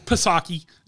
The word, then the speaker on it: Psaki: You've got old Circleback Psaki, you know, the... – Get it right, it's Psaki. the P is silent.